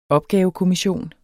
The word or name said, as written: opgavekommission